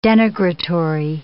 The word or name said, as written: denigratory